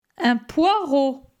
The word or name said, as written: Un poireau